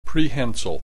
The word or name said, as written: prehensile